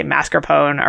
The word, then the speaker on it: mascarpone: And they were looking for mascarpone, as Kent pronounced it. Although I hear people say mascarpone around here usually. – Yeah, all the time. – I guess probably the Italians pronounce the E at the end because that's how Italian works.